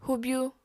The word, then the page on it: hubyu: apatani